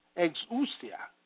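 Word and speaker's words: exousia (ἐξουσία): The Greek word for powers is exousia, pronounced exousia, meaning ability, privilege, force, capacity, mastery, magistrate, superhuman, potentate, token of control, delegated influence, authority, jurisdiction, liberty, power, right strength.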